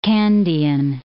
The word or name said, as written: Kandyan